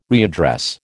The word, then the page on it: readdress: American English